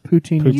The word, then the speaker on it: Poutine: Not poutine in your mouth. Poutine. – You said it right. I'm so proud of you.